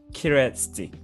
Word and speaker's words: Kereszti: And this is an interview with Thomas Kereszti.